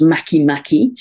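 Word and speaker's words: Makemake: It's spelled, spelled make make, but it's pronounced maki maki at eight degrees of Libra.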